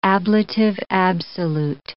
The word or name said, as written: ablative absolute